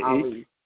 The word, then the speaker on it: Ali: Last name is Ali, A-L-I. A-H, H as in Heather. – A-L-I.